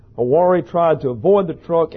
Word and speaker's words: Hywari: Hawari, H-Y-W-A-R-I is her name, pronounced Hawari, was driving to work headed west on I-64 about 7:30 a.m.